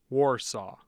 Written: Warsaw

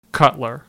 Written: Cutler

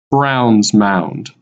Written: Browns Mound

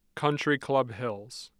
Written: Country Club Hills

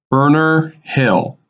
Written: Burner Hill

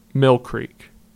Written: Mill Creek

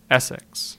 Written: Essex

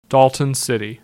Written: Dalton City

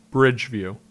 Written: Bridgeview

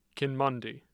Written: Kinmundy

